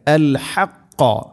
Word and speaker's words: الحق: ولا تلبسوا الحق بالباطل ولا تلبسوا الحق ഇവിടെ അൽ ഹഖ ഹഖ എന്നതിലെ ഹാവും ഖാഫും ഉച്ചാരണം പ്രത്യേകം ശ്രദ്ധിക്കണം.